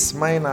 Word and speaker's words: Smyrna: It's Smyrna.